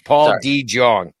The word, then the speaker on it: DeJong: I will Jong. You pronounce it wrong. Jong. Paul DeJong.